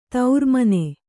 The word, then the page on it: ತಿರುಮಣ್ಣು: tiru maṇṇu